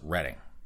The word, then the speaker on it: Reading: And if you folks in Pennsylvania pronounce it Reading, I apologize, up here in Massachusetts, even though it says Reading, everyone up here calls it Reading.